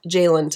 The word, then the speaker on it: Jaylin: All of my handles are just my name at Jaylin Tully,